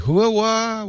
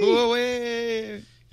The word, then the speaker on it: Huawei: Remember we used to say, Huawa, what is Huawei? – That? Huawei.